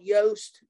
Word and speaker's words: Yoast: So all the way along, there's been a plugin called Yoast to do, like, it rhymes with toast, but it starts with a Y, to do SEO and help you with SEO.